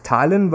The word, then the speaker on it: Tallinn: Und wie Aber es heißt Tallinn, weil die Esten grundsätzlich alles immer auf der ersten Silbe betonen, okay.